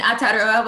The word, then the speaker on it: Aotearoa: Yeah, so I'm in Aotearoa, which is the indigenous name of New Zealand.